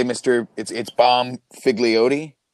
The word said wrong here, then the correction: Bonfiglio: No, Bomb Figlio. – How do you spell that, sir? – B-O-N-F-I-G-L-I-O. Yeah, baby. – But Mr. Bomb Figlio.